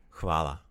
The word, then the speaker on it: Hvala: Hvala. That's kind of a tough one to pronounce. It's a very Croatian word.